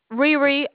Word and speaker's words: Rherhe: My name is Riri. How may I assist you? – You say your name is Rory? – Riri, R-H-E-R-H-E.